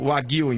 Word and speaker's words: Wagyu: Eu li outro, é Wagyu. – Wagyu, então, a pronúncia correta do gado.